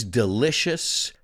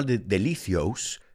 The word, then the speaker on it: delicious: I said these delicious, listen to the pronunciation, delicious, no delicios, delicious.